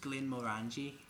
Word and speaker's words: Glenmorangie: It's pronounced Glenmorangie.